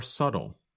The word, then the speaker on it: subtle: But other differences are more subtle. That's spelled S-U-B-T-L-E. But we do not pronounce the letter B in the word.